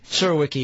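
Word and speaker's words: Surowiecki: Did I get the name right? – Zerwicki, yeah, it's close enough.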